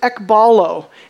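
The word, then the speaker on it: ekballo: He uses a word, it's pronounced ekbalo. It's where we get the word ball.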